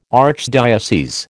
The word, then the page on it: archdiocese: American English